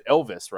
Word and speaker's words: Ilves: Is it Ilves, right? I'm saying it right. – Yep, Ilves. Yep.